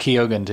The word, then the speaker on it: Keoghan: I caught Keoghan too.